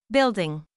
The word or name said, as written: building